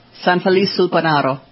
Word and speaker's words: San Felice sul Panaro: San Felice sul Panaro.